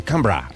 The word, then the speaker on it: Canberra: Que no se dice Canberra, se dice Canberra.